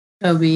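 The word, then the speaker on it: Jacobi: is it pronounced Jacoby? – Jacoby, yeah.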